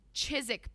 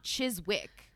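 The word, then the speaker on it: Chiswick: But I'm saying Chizik because I don't think you pronounce the W, right? It's not Chizwick.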